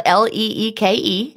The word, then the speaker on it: Leeke: Leeke is spelled L E E K E, everybody.